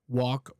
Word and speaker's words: walk: But I would say that I've never heard anyone saying the L. So I would say that that's a pretty standard, pretty international way of saying the word walk or talk.